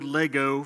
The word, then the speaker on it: Lego: And I don't know if you knew this, but you don't pronounce it Legos. It's Lego, even if it's plural, and I learned that, I guess, this last year or two.